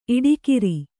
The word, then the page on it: ಈಡುಕಾರ: kannada